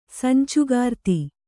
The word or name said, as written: ಸಂಚುಗಾರ್ತಿ